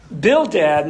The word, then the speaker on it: Bildad: Bildad, and that's actually how you pronounce the name here, by the way, told him that God rewards those who are good, essentially saying the identical thing that Eliphaz is saying.